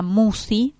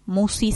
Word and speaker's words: Musci: Y en la semana subimos el programa al canal de YouTube que lleva mi nombre, Marisa Musi. Musi se escribe M-U-S-C-I.